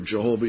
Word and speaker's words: Jehovah: Our Elohim is, you can pronounce it Yahweh or Jehovah, either one, Yahweh, Yahweh, or transliterated, our one God is Jehovah, Jehovah.